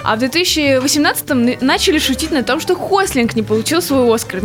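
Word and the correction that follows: Gosling: Я правильно сказала, нет? – Я... Где у меня третья рука для третьего фейспалма? Я не могу найти. – Поправьте меня. – Ну, если что, он Гослинг. – Гослинг, блин, почему я путаю Гейх?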